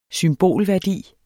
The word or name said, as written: symbolværdi